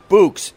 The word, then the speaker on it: Bouqs: Books is short for bouquets or bouquets, depending how you say it, but I could spell it right for you. B-O-U-Q-S.